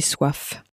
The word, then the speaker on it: soif: And it's spelt S-O-I-F. The word for hunger was... – Faim. – Spelt F-A-I-M. Now, you may notice that the F on the end of soif was very clearly pronounced.